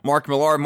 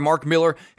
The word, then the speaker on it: Millar: Mark Millar, Mark Miller, however you want to say it. Mark Millar on Twitter back in 2010 actually tweeted that both pronunciations of his last name are correct and both spellings are technically correct. Historically, it's a trade name and was pronounced differently by region is what he said.